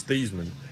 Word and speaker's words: Theismann: The way you pronounce his name is Theismann, but they wanted to do a Heisman campaign for him at Notre Dame, and he became Theismann.